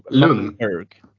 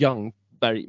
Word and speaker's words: Ljungberg: Eh, eller ja, Ljungberg var ju svinbra så det gör väl inget men då uttalar han det Lung. Alltså Lung, Lungberg. Ja lung. exakt. Och då vet jag Anders Jansson då, vår allas gode glada Anders Jansson, är på honom på Twitter och så här Tim, It's pronounced eh, typ som Young, alltså Young på engelska.